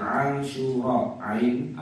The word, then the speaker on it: Asyura: Nah, jadi Asyura, ain, alif, syin, wawu, ra, alif, ada hamzahnya di belakang. Jadi bacanya a syu ra a. Nah, itu ada qalqarah di belakang. Asyura gitu. Artinya hari yang ke-10. Tapi orang Jawa mungkin susah untuk mengucapkannya dengan benar, akhirnya jadi suro.